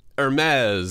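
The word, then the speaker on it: Hermès: That's how you pronounce it, right? Hermes? – Oh, come on. Hermes.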